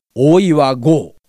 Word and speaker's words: 大岩剛: パリオリンピックといえば、今週はサッカーのアンダー 23 日本代表がオリンピックの予選で勝ちましたけども、今このアンダー 23のチームの監督を務めているのが大岩剛監督、あの長渕剛さんの剛と書いて剛と読ませるんですけども、大岩剛、音の響きも字面もごっついでしょ。